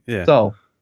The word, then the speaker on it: Seoul: Korean film located in Seoul. That's how you say it, isn't it? Korean Seoul. – City. – Seoul.